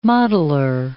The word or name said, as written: mottler